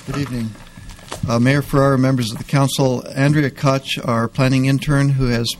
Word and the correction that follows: Koch: Cook, excuse me. I don't know why I want to pronounce it wrong.